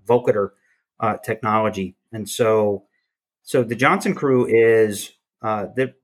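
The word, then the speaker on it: Jonzun Crew: And so this is the Johnson Crew, and it's spelled J-O-N-Z-U-N Crew.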